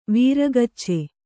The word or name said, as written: ವೀರಗಚ್ಚೆ